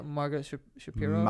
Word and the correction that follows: Magomed Sharapov: Magomed Sharapov. – I thought it was like Shapirov or something. – Nah, Sharapov.